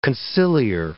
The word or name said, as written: conciliar